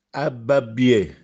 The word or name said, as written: Abbabbiè